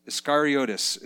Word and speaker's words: Iscariotus: Anybody know how to pronounce Iscariot? Iscario. It's actually in Greek, it's it's Iscariotus. Iscariotus is how it's pronounced.